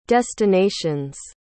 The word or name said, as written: destinations